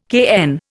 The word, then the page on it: cayenne: Indian